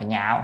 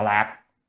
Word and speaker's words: nhạo: Chữ này đọc là nhạo, không đọc là lạc, nhạo mới yêu thích.